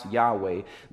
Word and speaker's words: Yahweh: Now, this name in the original text would be pronounced Yahweh.